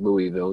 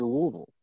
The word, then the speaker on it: Louisville: I currently live in the city of Louisville, and it's not pronounced Louisville. It's Louisville, and I know it's Louisville.